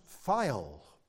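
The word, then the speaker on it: phial (φιάλη): Now, the Greek word is pronounced phial. And that gives you an English word almost directly.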